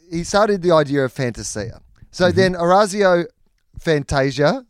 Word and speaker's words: Fantasia: And he said, well, technically, it's Fantasia.